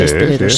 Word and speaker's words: esteleros: Dice Inma, en Canarias a los componedores de huesos se les llama esteleros. ¿Lo he dicho bien? Sí, sí, esteleros. Sí, sí. Estelero, sí.